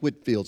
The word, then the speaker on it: Whitefield: If you're looking at a piece of paper, it looks like White, W-H-I-T-E, Whitefield. But scholars of the day say it's pronounced Whitfield, so that's the way I'm going to say it. George Whitefield evidently was one of the most amazing public speakers ever to walk on this earth.